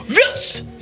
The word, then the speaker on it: Wurst: Là, W-U-R-S-T. – Eh ben, je dirais tout simplement Wurst. – Wurst.